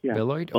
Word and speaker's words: Beloit: Your Pastoring Community Bible Church in, how do you pronounce it, Beloit? – Beloit, Ohio. – Okay. Beloit, Ohio, right.